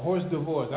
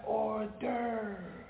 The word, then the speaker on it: hors d'oeuvres: Horse, you look at the way that it's written, horse d'oeuvres or horse divorce. I would just say horse divorce, right? But then one day, a grown man, oh, that's hors d'oeuvres, right? Filet mignon, how you spell that? M-I-G-O-N. How do you spell sign? S-I-G-N-S.